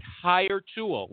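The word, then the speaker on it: HireTual: For our good friend, Nin, N-I-N-H, with the unnecessary H, Nintran, okay? And he is the COO over at Hire Tool. Let me spell it for you. H-I-R-E-T-U-A-L. H-I-R-E-T-U-A-L.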